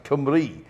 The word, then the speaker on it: Cymru: No, it's, it's pronounced, yeah, it's, it's spelt Kimru, but it's pronounced Cymru.